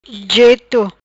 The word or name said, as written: Jeto